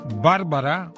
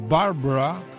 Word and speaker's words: Bárbara: Bárbara o Barbara, de acuerdo con la pronunciación, se ha convertido en un poderoso huracán.